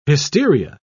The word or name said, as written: hysteria